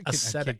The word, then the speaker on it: ascetic: How do you say that? – Ascetic.